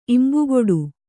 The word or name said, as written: ಇಂಬುಕೊಡು